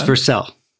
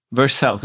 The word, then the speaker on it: Vercel: Did I pronounce it correctly this time? It's Vercel. – Vercel.